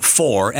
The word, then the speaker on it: Fore: Huey Lewis in the News, and it is off the album Four, F-O-R-E. So I guess you got to say it like four. – Yeah, like in golf.